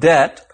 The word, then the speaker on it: debt: That's when debt, formerly spelled D-E-T-T-E, got its silent B, and so became more like its Latin root debitum.